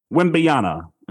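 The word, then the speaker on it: Wembanyama: Victor Wimbiana.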